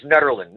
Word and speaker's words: Nederland: Like most people don't talk about Nederland, where this, I hope I'm saying that correctly, Nederland. – Actually, it's Nederland.